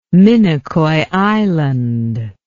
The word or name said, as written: minicoy island